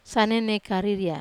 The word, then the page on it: sane nei kariria: wamesa